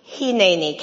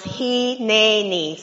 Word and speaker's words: Hineni: And so we're going to do a short Hebrew lesson on just one word, Hineni. Can you say it with me? Pronounce Hineni.